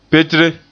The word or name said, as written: petre